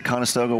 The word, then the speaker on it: Conestoga: Conestoga wagon.